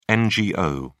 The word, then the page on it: NGO: British English